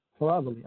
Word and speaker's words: Caravale: That's called Caravale. – Caravale. Okay. I was saying it wrong.